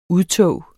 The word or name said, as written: UDTOGENE